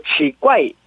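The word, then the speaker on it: 지괴 (奇怪): 그래서 발음은 저 이성과 사성과 그 지괴 기괴를 지괴라고 읽고 이상하다, 요상하다.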